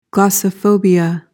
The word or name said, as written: glossophobia